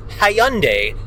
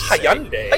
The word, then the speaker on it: Hyundai: Ideally suited for a model named after a country, city, or state, like the Hyundai Santa Fe. – I don't know if that's how it's pronounced. – It's not. I just wanted to Hyundai.